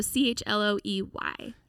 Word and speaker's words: Chloey: Yeah, Chloey Wilson. And it's Chloey with a Y. So Chloey.